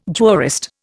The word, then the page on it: jurist: Indian